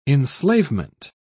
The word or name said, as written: enslavement